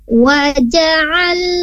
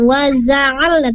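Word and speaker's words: Waja'alna (وجعلنا): Alhamdulillah, sedikit perbaikan di huruf jimnya. Waja'alna. Nah, di sana jim bukan zai ya, bukan waza'alna, tapi waja'alna. Untuk huruf jim, pengucapannya tengah-tengah lidah diangkat ke langit-langit atas.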